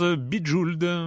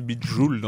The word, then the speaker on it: BJ Weld: BJ Weld se prononce Bijould, Bijould. Voilà, oui c'est vrai. On, avait compris en même temps. – on a bah oui tout le monde a compris, mais c'est vrai qu'il faut le prononcer Bijould en anglais.